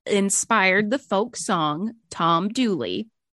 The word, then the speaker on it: Dula: Her lover, whose name is spelled D-U-L-A, like doula, but everyone insists it's pronounced Dooley. So the spelling in the song and kind of historically is Dooley, D-O-O-L-E-Y.